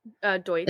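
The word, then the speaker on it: Deutch: Zoe Dutch? – Deutsch. – Is it Dutch or Deutsch?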